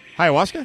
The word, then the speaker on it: ayahuasca: And let's talk about Aaron Rodgers using ayahuasca.